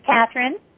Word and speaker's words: Catherine: This is Catherine.